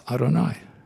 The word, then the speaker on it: Adonai: But when they are placed next to each other in a prayer, they're pronounced Adonai.